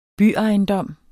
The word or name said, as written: byejendom